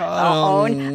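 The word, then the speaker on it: onn: On uh, um, own.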